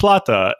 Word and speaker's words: plata: Me saying plate made people upset, and I will explain why I've said plate and not plata, and that is because in the earliest English translations from Spanish to English, they used plate, P-L-A-T-E, not plata. You would not pronounce the word plate as plata, therefore plata. Plate. Yeah, I am aware that in Spanish it is plata, as in silver.